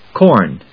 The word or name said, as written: corn